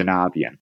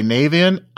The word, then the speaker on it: Navien: the real correct way to say it is consider how or think about how you say the word navigate because that's really an acronym where we got it from. So uh, navigate is Navian.